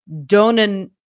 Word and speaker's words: donanemab: D-O-N-A-N-E-M-A-B. – Like you said it, but faster.